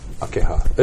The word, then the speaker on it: Akeha: A-K-E-H-A. – Akeha. Right on. I got it right. – Akeha.